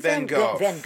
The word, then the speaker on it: Van Gogh: Didn't Van Gogh. Yes, say it like the Dutch.